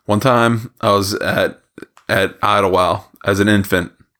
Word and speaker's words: Idlewild: It is so Pittsburgh to pronounce it Idlewild. There's a D on the end, but we all just collectively agreed, no thank you, on that D. – Idlewild.